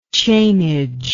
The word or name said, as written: chainage